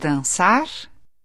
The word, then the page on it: dansa: [ˈdansˌa]